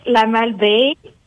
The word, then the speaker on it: La Malbaie: It's La Malbaie. It's in Quebec, Canada. – Okay, now, can you spell it again for me? N-A-L? – It's M, like Mary.